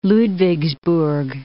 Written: Ludwigsburg

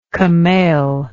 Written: camailed

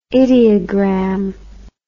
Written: idiogram

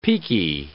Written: peaky

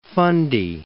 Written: Fundy, Bay of

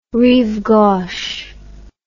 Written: Rive Gauche

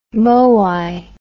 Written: moai

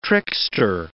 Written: trickster

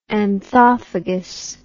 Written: anthophagy

